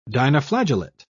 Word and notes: dinoflagellate: British English

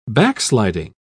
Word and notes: backsliding: British English

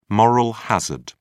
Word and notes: moral hazard: British English

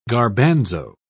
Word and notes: garbanzo: British English

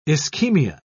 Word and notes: ischemia: British English

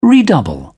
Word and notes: redouble: British English

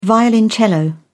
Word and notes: violoncello: British English

